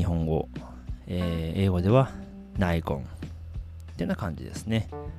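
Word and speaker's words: Nikon: ナイコン。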